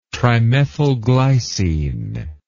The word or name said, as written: trimethylglycine